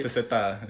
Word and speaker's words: SZA: O Sisa, no sé si lo estoy pronunciando bien y me estoy escuchando bien pendejo, güey. – SZA. – SZA, sí, mo, porque hubo un pinche gringo de que, güey.